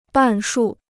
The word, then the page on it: 半数: bàn shù